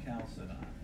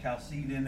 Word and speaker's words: Chalcedon: Definition of Chalcedon. Or Chalcedon, it depends on who you talk to. I've heard it both ways. It depends on the teacher you listen to and how they'll pronounce. I say Chalcedon. That's what I've always said. I know it sounds like a dinosaur.